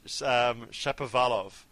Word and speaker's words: Shapovalov: So he says Shapovalov. And the emphasis on the va in the middle of it all.